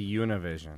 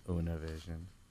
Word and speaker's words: Univision: Is that how you say it? – No. Well, you say Univision. – Just like Usette. – Yeah, Univision.